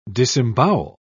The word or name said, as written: disembowel